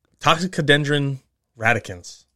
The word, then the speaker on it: Toxicodendron radicans: Uh, Toxicodendron radicans.